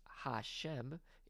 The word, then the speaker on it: Hashem: So you can just call it the four letters, Tetragrammaton in Latin, or sometimes the name, which is pronounced Hashem in Hebrew, because you don't really want to vocalize it if you're an ancient Jewish person.